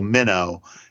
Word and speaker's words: meno: But I should say it's not meno like the fish. It's not spelled right. That way. It's M-E-N-O, and if we were doing it, we'd put a line over it to say it's a long O. So M-E-N-O, minnow, is the verb form of this.